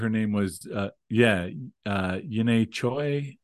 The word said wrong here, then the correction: Yejin Choi: I don't know that I'm pronouncing that right, but I think it was something... Yejin Choi. I just looked it up. Yejin Choi.